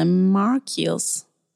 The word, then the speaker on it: Marcuse: Men han bodde ju väldigt länge i USA, så jag tror att väldigt många säger Marcuse. – Men mm. – nu, det Marcus. tyska uttalet precis. måste vara Marcus.